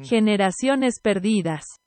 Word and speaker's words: Generaciones Perdidas: Generaciones Perdidas. – Oh, yeah, it's H sound, my bad.